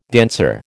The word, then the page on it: danseur: American English